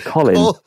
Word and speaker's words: Colin: It's, like Colin Powell. Like, no, it's Colin. Colin. – Colin Powell. I didn't even get that one. Colin.